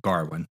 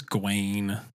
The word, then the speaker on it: Gawain: Or Gawain, as you pronounce, they pronounce it Garwin in the movie. – I'm pretty sure it's Gawain in the original, like, text, I guess.